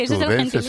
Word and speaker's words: azudenses: Les da la vida a la biblioteca, a los propios azudenses, ¿se dice así? – Sí, así se dice, azudenses, es el gentilicio.